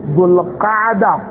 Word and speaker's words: Dhul Qaada: dhul qaada qaf ni qaf dhul qaada o su ko kan la ni ye o su ke jila dhul hijja. That is the correct way of pronouncing those months.